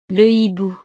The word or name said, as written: le hibou